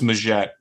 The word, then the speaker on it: Maggette: Josh Maggette playing 12 minutes a game. – That's pronounced Maggette, but Maggette.